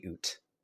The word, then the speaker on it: OoT: Except potentially Oot. That's the only one Oot. I sometimes Oot. – Ocarina of Time to say Oot on occasion.